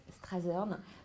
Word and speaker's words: Strathern: en particulier lorsque Strathern, c'est comme ça qu'on prononce, hein, Strathern,